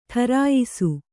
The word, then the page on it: ಟರಾಯಿಸು: ṭarāyisu